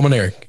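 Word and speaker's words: Mainieri: Is that how you say it? – Yes, Paul Maneri.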